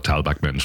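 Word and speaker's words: Tal Bachman: Vom Namen her offenbar ein Gewässerexperte von ganz weit unten, der Tal Bachmann, so liest er sich zumindest. Aber Tal Bachmann spricht man ihn aus.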